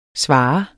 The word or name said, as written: svare